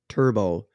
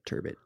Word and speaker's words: turbot: The majority of people are pronouncing it turbo, French. The French pronunciation of it is turbo. That to leave that T, so it's a turbo.